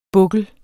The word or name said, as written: bukkel